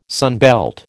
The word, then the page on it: sunbelt: American English